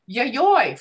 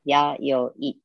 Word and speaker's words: Yayoi: Oh, yayoi, yes. – Ya. – So in Japanese characters, you would write the character for ya, yo, and I. So it's yayoi.